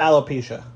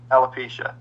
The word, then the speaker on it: Alopecia: Alopecia, right? Is that how you say it? – Alopecia. – Alopecia.